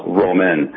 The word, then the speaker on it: Roman: So you got the name of the restaurant exactly right, but my last name is pronounced Roman, like Roman Catholic.